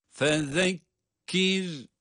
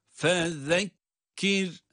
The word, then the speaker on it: Fezekkir: Fezekkir. Fezekkir. – Zal peltek. Peltek okunuyor zaten. – Fezekkir. – Sonra da cezimli olan ra kendisinden önce esri olması itibariyle ince okunuyor.